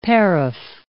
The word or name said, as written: paraph